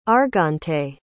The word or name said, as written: argante